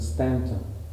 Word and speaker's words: Staunton: The Southerners pronounce it Staunton. I always say Staunton, but it is Staunton, Virginia, and I did go there out of my own choice, and I stayed there for 4 years.